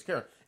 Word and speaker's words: Karon: Yeah, it's like K A R O N or something like that, but it's Karen.